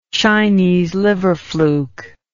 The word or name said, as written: chinese liver fluke